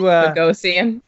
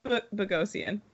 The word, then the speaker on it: Bogosian: You know, I did like what Kyle brought up about Bezogan or whatever the hell his name is. But he went to Bogosian. – Yeah, thank you. He went to Tampa because they get everybody. – How did you say it? – If you look. – Huh? – What was that again? What was his name again? – Bogosian. – There you go.